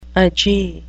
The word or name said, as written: agee